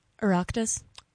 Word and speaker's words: Oireachtas: Oireachtas? – That is correct.